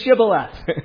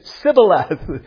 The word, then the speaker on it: Shibboleth: And they asked them, say Shibboleth. And the people from Galilee couldn't say that. They said Sibboleth. They couldn't say Sh.